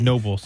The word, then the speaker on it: Knoebels: He says it's Nobles. The K is silent.